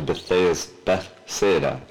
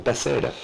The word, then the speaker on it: Bethsaida: And go on ahead of him to Bethsaida. I said that wrong. Mm-hmm. Bethsaida.